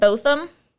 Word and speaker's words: Botham: I'm not entirely sure that's how you pronounce it, but when I looked it up, it said Botham. In both British and American. So that is what I am going to go with, and I apologize if that is incorrect.